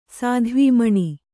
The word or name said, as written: ಸಾಧ್ವೀಮಣಿ